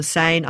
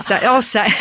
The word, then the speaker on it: Seine: He lived on a barge boat on the Seine under the shadow of the Eiffel Tower. – Seine. I said Seine, didn't I? – Seine.